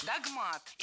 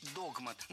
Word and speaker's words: догмат: Догмат. И это верно. Сейчас уже верно. Хотя раньше следовало говорить догмат. Но филологи пересмотрели ударение в этом слове и приняли двойную норму. Поэтому ошибиться в этом слове невозможно. И догмат, и догмат находятся в пределах литературной нормы.